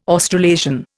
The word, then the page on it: Australasian: Indian